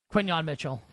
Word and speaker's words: Quinyon Mitchell: How do I pronounce this guy's name? Quinyon Mitchell? – Quinyon Mitchell.